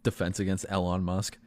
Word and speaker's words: Elon: I'm talking Elon Musk. Is that how you say his name? – Yeah. – Oh no, it's Elon. – Elon.